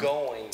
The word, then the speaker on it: Goins: I'm going to get that new Jeff Goins book, though, and hopefully it's pronounced Goins.